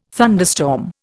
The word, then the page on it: thunderstorm: Indian